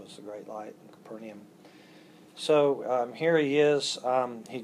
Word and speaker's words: Capernaum: There's two ways to pronounce that, but I'll pronounce it Capernaum.